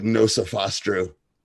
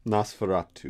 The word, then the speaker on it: Nosferatu: How'd you say that? – I can never pronounce that right. You pronounce it. – Nosferatu. – Nosferatu. What did I say? Nosferatu? – I don't know. He added a couple extra S's, I think.